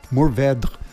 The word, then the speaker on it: Mourvèdre: Mourvèdre. But you've heard of that one. – I have, and I love it. Mourvèdre, as the French pronounce it, is a sensational red grape.